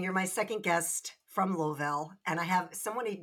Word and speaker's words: Louisville: So I feel like with Louisville, it's not Louisville, it's Louisville.